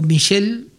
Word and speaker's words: Michel: Él, no sé cómo se pronunciará en griego, pero es Mikael o Michel, uh-huh, porque es con CH, como si dijeras Michael en inglés.